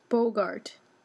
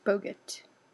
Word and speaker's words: Boggart: How do you pronounce it? – Boggart. Boggart. Boggart. That's how you say it. Boggart.